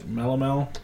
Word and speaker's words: Melomel: But when you add fruits to it, they call it a is that, am I pronouncing that right? Melomel? – Yeah.